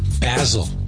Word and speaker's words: Basil: Yeah, she actually pronounces it Basil. Most likely because she doesn't want to be named after an herb.